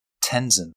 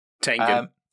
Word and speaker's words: Tengen: Guts from Berserk against Tenzin from Demon Slayer. I honestly forgot his name for a moment. – Tengen.